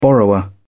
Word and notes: borrower: British English

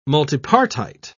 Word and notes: multipartite: British English

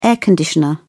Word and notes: air conditioner: British English

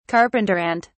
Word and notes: carpenter ant: British English

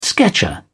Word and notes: sketcher: British English

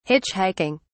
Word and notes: hitchhiking: British English

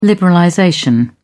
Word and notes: liberalization: British English

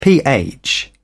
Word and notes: ph: British English

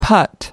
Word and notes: putt: American English

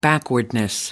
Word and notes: backwardness: British English

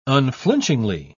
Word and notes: unflinchingly: British English

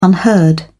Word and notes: unheard: British English

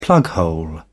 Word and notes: plughole: British English

